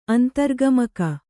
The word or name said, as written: ಅಂತರ್ಗಮಕ